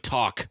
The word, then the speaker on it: talk: No, the L in talk. Try and pronounce. Try and, when you were a kid, you had to go through fucking kindergarten. Everybody's like, sound it out when you spell. Yeah, if I was to sound out words, they'd be spelling correctly. – Talk. How do you spell talk? How would you spell it? T-O-C-K?